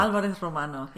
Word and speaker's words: Alvarez-Romano: Yes, Alvarez-Romano, yes.